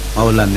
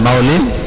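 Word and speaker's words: maulan (مولًى): Maulan ya bacanya itu. Maulan am maulan gitu ya. Ya, apa boleh dibaca maulan an maulin? Boleh.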